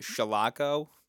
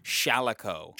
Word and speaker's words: Shalako: And I forgive me, last week I pronounced it Shalako. Forgive me. It's Shalako.